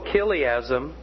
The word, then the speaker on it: chiliasm: And this is spelled C-H-I-L-I-A-S-M, and it's pronounced chiliasm.